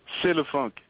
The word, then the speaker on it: C'est le funk: It's C.S. LaFunk? – C'est le funk. – C'est le funk. Okay. – C'est le funk. You know, like c'est la vie? That's the same thing.